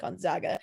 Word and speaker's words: Gonzaga: I said it right this time, Jake. I think Gonzaga.